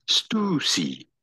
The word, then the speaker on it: Stussy: Not say juicy, but rather stoosy.